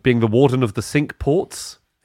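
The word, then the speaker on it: Cinque: It does mean five, and it's spelt like the French for five, but it is, I swear to God, they pronounce it warden of the sink ports.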